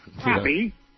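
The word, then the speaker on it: crappie: So, crappy, but it's spelled crappy. – Yeah, exactly. – P-P-I-E, yeah.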